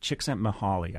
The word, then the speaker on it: Csikszentmihalyi: You cite the work of a psychologist whose name I will almost be guaranteed to mispronounce, Mihaly Csikszentmihalyi, who's written about what he calls flow. – Yeah. – How close did I come? – Csikszentmihalyi, I think, mm-hmm.